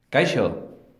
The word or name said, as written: Kaixo